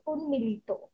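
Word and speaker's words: UNMILITO (উন্মীলিত): So if I do not pronounce it in a very anglicized way, it's UNMILITO.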